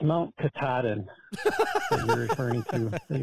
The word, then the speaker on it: Katahdin: But it's Mount Katahdin. Thanks. – Well, thank you for supporting us on Patreon. – Yeah, we're sorry, because I was calling like Cat Haddon.